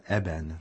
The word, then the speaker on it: Eben: We have a CD of music for children's choir written by the highly distinguished contemporary Czech composer Petr Eben. That's spelled E-B-E-N.